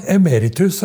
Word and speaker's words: emeritus: Men du har jo rett uttalen av emeritus, og lett trykket på rett plass nå. Ja, så det? Ja. Emeritus, det er det det uttales.